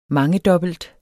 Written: mangedobbelt

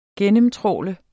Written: gennemtrawle

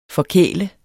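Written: FORKÆLEDE

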